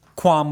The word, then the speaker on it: qualm: Somebody yelled at us on Twitter for like, you're allowed to say qualm with no L.